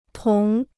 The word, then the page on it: 同: tóng